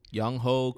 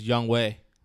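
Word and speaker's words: Younghoe: Even though his name is not pronounced Young Ho. – I know it's not pronounced but Young Ho. But as a fellow American, you read it as Young Ho. – Can you pronounce it the real way? – It's Young Way.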